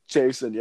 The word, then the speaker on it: Chaisson: Uh, how do you say that, Chaisson? – Chaisson, yeah.